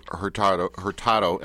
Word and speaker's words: Hurtado: It's a Spanish name, so if you were pronouncing it in its Spanish, it would be Hurtado. – Hurtado. Hurtado. – But the anglicized form is Hurtado.